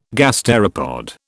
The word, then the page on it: gasteropod: British English